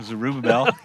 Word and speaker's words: Zerubbabel: And the very first wave, Zerubbabel, if I said that correctly, goes back. – Zerubbabel.